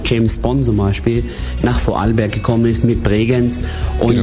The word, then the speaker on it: Vorarlberg: Vorarlberg, so spricht man. Vorarlberg, genau.